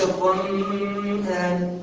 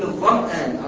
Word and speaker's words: الظمآن: الظمآن، ما بقولش الظمآن، بقول الظمآن على طول.